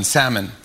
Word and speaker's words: salmon: She says, My date pronounced the L in salmon. And I said out loud, Well, you're not the one.